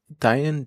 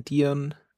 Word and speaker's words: Dion: I spricht man wie I, Dion, Dion, Dion.